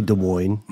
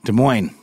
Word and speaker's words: Des Moines: Is it, is it you pronounce the S? I say Des Moines. Des Moines.